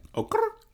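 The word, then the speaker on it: Okurrr: Okr. Is that how you say it? – Okr. – Oh, it's okr. – Okr. – Okr. – Okr. – Okr. – Yeah. – Okay, okr. Mm-hmm. Oh, it's spelled O-K-U-R. R-R-R. – Three R's.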